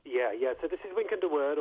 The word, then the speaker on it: Wynkyn de Worde: The first chapter about printing introduces the person, and forgive me if I mispronounce his name, Wynkyn de Worde. – That's good. Let's go with that.